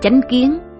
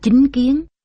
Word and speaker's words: chánh kiến: Một, thực hành chánh kiến. Chánh kiến đôi khi cũng đọc là chính kiến, với ý nghĩa là quan niệm, cách nhận hiểu vấn đề một cách chân chánh.